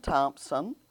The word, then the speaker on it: Thompson: I'm Joanne Woodson Thompson, J-O-A-N-N, all together, no capital A, Woodson, W-O-O-D-S-O-N, Thompson, T-H-O-M-P-S-O-N.